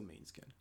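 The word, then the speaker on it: Måneskin: It is pronounced a long A sound. – So, okay, so Maneskin. All right. Which there's an E in there already, so it's already going to be a long A.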